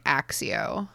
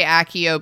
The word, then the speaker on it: Accio: Before the films, I definitely pronounced this letter as if it were an X. Like I did like Accio. – Accio. – But now I'm pretty, I tend to say Accio because of the films.